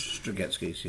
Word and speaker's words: Strugatsky: Strugatsky brothers? – Strugatsky's, yeah.